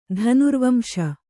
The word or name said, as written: ಧನುರ್ವಂಶ